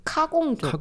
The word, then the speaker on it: 카공족: And when you abbreviate it to 카공족, you have to pronounce it as 카.